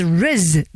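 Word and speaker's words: rizz: The Oxford word of the year is riz. Do you know what that means? It rhymes with fizz, so I like it already.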